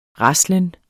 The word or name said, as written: raslen